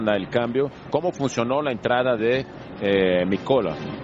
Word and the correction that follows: Micolta: Micolta. Micolta, Micolta. Te faltó una T y me asusté.